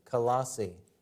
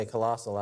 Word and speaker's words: Colossae: And the letter or the epistle that was written was sent to those saints in Colossae. We say Colossal out here, but I guess the real pronunciation is Colossae.